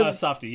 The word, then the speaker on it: Safdie: is it Safdie? Is that how you pronounce it? – Safdie, yeah.